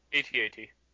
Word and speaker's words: AT-AT: AT-AT.